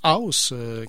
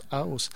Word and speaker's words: Huis: Et pour l'instant, ben, on va y aller avec un petit bloc qui va comprendre le groupe de Montréal qu'on prononce House, qui s'écrit H-U-I-S, mais qu'on prononce House.